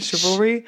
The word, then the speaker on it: chivalry: Chivalry. – Yeah. – Is that how you say it? Chivalry. – Yeah.